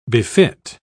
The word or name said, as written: befit